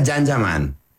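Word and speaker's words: Can Yaman: Se escribe Kanyaman, se pronuncia Janjaman.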